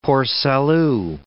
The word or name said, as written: Port Salut